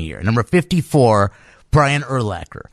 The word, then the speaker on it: Urlacher: They say Urlacher. It's Urlacher. And it's so easy to remember because it rhymes with linebacker.